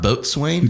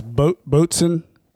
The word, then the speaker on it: Boatswain: It's not Boatswain. – It's not Boatswain. – It looks like Boatswain. – Yeah, that's how it's spelled. – It says Boson.